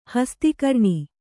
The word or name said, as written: ಹಸ್ತಿಕರ್ಣಿ